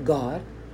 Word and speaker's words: Gad: they say they spell G-A-W-D, God, or G-O-D pronounced God. G-A-D pronounced God was the name of a deity. And there was one of, was it Isaac's son? Gad, God. Dan, Don.